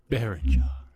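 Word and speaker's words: Berninger: Berringer.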